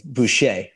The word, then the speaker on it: Boucher: It's Boucher, like Bobby Boucher in the Water Boys.